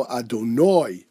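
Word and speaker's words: Adonoi (אֲדֹנָי): But otherwise, the Siddur Yaivitzi brings down that you should pronounce it with an oi, and Ashkenazi should not use the Sfaradi Havara, the pronunciation of a Sfaradi, unless he can make that true distinction. Also, the Mishnah Berurah points out that the pronunciation is towards the end of the word. It's a milra, not mil'el, which means, again, I'll read the pasuk, Baruch ato Adonoi lamdeni chukecha.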